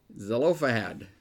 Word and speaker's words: Zelophehad: Zelophehad.